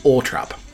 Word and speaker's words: Althorp: And the uh, public pronunciation of Princess Diana's home, Althorp, in uh, Nottingham, uh, sorry, Northamptonshire, irked the Spencers for years. In 1993, Charles Spencer wrote to the BBC pronouncing uh, pronunciation unit. I didn't know the BBC had a pronunciation unit, blaming public laziness, insisting it should be Ortrap.